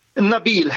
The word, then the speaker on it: Nabil: Hej, jag vill få hjälp med att uttala ditt namn. Nabil eller Nabil? – Nabil heter jag.